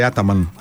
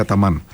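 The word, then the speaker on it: Ataman: Πάντως η μισή Ελλάδα άρχισε να τον λέει Άταμαν τα τελευταία χρόνια, ενώ όλοι τον είχαμε μάθει Αταμάν. Και άλλους, έτσι όπως έχουν αλλάξει η προφορά κτλ.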